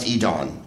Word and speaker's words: Edan: Dear Greg, this is from Edan, pronounced Edon, pronounced like Edon. Okay, so I could say Edan then and go, it was kind of like Edon. Edon.